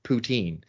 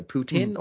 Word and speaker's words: poutine: So there's a pronunciation war about poutine, because in Quebec, the French pronunciation is poutine.